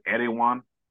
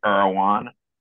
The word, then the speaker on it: Erewhon: Or like I like to call it Erewhon, but my daughter keeps telling me that that's not how you say it. You say Erewhon.